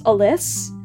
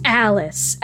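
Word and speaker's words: Alyss: And when we were doing this scene in the table read, Steven, who played the character, pronounced Alice as Alice. And before she said her actual line, Naya, who plays Alice, went, "It's Alice," and then goes into the rest of her line.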